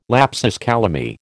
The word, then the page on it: lapsus calami: American English